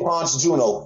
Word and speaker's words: Ponce Juno: 2K Ponce Juno. Ponce is spelled P-O-N-C-E, and it's J-U-N-O.